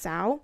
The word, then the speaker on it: Zhao: I thought it was pronounced Zhao, but I think it's pronounced with more of like a ch, like a Chloe Zhao.